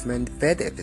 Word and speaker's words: Medvedev: Medvedev is how you pronounce his name. Finally, I get it.